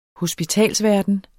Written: hospitalsverden

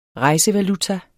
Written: rejsevaluta

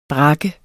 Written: brak

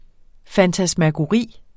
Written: FANTASMAGORIERNE

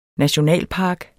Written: nationalpark

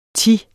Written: thi

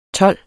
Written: tolv

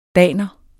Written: daner

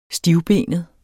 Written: stivbenet

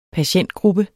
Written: patientgruppe

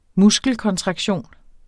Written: muskelkontraktion

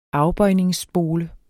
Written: afbøjningsspole

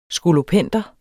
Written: skolopender

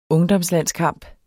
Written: ungdomslandskamp

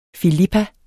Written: filippa

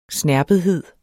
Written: snerpethed

